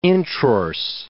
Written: introrse

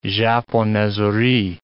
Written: japonaiserie

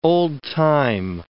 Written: old-time

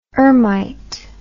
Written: ermite

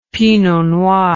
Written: pinot noir

